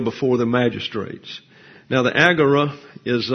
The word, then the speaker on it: agora: It comes into the English as Agora in your dictionaries, and it's often mispronounced as the Agora. It's not an omega here. It's an omicron. So it can't be agora. It's agora. See, this, the omicron is like a short A sound.